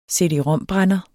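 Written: CD-ROM-brænder

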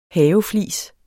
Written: haveflis

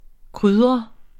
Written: krydret

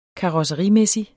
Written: karosserimæssig

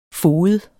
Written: foged

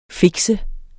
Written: fikse

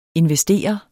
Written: INVESTER

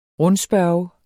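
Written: RUNDSPØRGET

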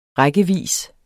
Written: rækkevis

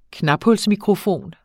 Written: knaphulsmikrofon

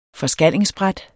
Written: forskallingsbræt

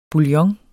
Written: bouillon

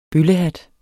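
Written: BØLLEHAT

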